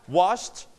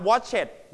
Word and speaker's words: washed: And for regular verb lists today, we have washed. Repeat after me. Wash. Brush. Crash. เสียง S S จะเสียงยาวนะครับ Shh. So when you put ed, you pronounce watch it. ไหมครับ Watch it ไหม Watch it ไหมไม่นะครับห้ามออกเสียงว่า watch it เด็ดขาด So what How to pronounce this word? Wash with ed. We pronounce t, นะครับ t. Washed. Washed.